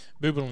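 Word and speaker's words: Boublil: How do you pronounce it? – Bubel and Schoenberg. – Schoenberg. And it has the umlaut.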